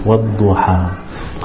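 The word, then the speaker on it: Wadduha (والضحى): So when you pronounce it, it should end in between the tongue and the roof. Wadduha.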